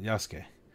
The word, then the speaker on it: Yasuke: I think that's how you pronounce it, Yasuke.